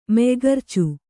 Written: ಮೆಯ್ಗರ್ಚು

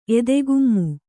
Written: ಎದೆಗುಮ್ಮು

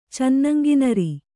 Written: ಚನ್ನಂಗಿನರಿ

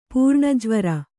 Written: ಪುರಾಣಜ್ವರ

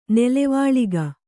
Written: ನೆಲವಾಳಿಗೆ

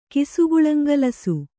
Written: ಕಿಸುಗುಳಂಗಲಸು

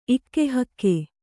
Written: ಇಕ್ಕೆಹಕ್ಕೆ

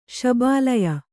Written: ಶಬಾಲಯ

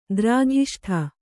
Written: ದ್ರಾಘಿಷ್ಠ

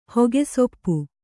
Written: ಹೊಗೆಸೊಪ್ಪು

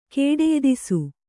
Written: ಕೇಡೆಯ್ದಿಸು

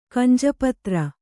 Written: ಕಂಜಪತ್ರ

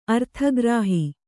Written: ಅರ್ಥಗ್ರಾಹಿ

